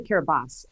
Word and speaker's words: Kiribati: Why do you need as a sponsor nations like Tonga, Kiribati, and Nauru? – Um, it's a uh, well, it's actually Kiribati, uh, but it is spelled Kiribati.